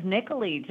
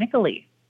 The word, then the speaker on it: Nicolee: It is Nicolee. Just think Nicole with two E's, Lee at the end, Nicolee.